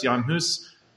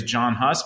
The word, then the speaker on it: Jan Hus: Jan Hus, which some people say is John Huss, but he's not English, he's Czech. And so we're going to pronounce his name like he would pronounce his name.